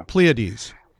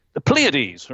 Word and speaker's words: Pleiades: Oh, sorry, how do you pronounce that? How would you pronounce that as an American? The meteorite shower. – Pleiades. – The Pleiades, right?